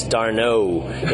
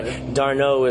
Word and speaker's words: Darno: Yes, Chase Darno. The emphasis on the last syllable, but you know, Darno is the right ending.